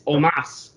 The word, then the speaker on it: Omos: I don't know about Rob, but we've been stressing the O, because that's how it's spelled, Omos. It's Omos.